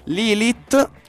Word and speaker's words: Lilith: Lilith, appunto di cui stavamo parlando. La T si pronuncia, ok, basta che... – Sì, è, è, ebraico, non è, non è, inglese, diciamo così.